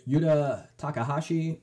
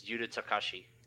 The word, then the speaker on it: Yuta Takahashi: And big congrats to, and my apologies for mispronouncing, probably, Yuta Takahashi for winning with Is It Dragons, winning against Temur Treasures. – You were very close. It's Yuta Takahashi.